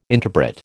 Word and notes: interpret: American English

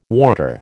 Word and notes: warder: American English

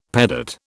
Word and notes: pedate: British English

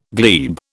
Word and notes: glebe: British English

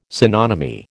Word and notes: synonymy: American English

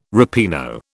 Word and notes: ripieno: British English

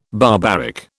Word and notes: barbaric: British English

